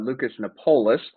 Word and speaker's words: Lucas Nopolous: So it is Lucas Nopolous, but honestly, you would have been in the minority if you would have pronounced it correct the first time, so don't feel bad at all.